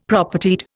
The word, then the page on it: Propertied: Indian